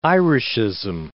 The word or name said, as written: Irishism